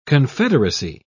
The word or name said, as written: confederacy